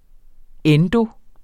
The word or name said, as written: endo-